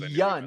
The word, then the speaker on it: Yeun: It's Yeun. I watched the video Steven right before this. Yeun. Mess it up. It's Yeun. Steven Yeun.